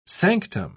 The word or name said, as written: sanctum